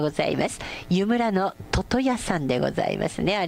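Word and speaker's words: 魚矢: 湯村の、魚と矢と書いて、読み方はトトヤで合ってますよって、よかった、トトヤさんなんだ、もっちゃんトトヤさん、はい、ありがとうございます。ジャングルネーム通勤リスナーさん、教えていただきましてありがとうございます。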